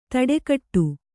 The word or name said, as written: ಥಟ್ಟುಗೆಡು